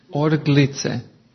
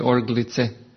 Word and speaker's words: orglice: How do you say that in Slovenian? Orlica. Orglice. With the G. Orglica. Ili orglice. Orglice.